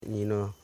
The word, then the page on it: ninan: ni.nan